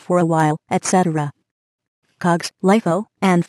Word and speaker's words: LIFO: LIFO, Last In, First Out, pronounced LIFO.